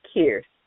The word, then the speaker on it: Keaise: Sheila is spelled Shiela, and my last name is spelled Keaise, pronounced Kears. Yes, the I is pronounced as an R.